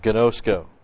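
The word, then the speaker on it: ginosko (γινώσκω): G-I-N, long O, S-K, long O, ginosko.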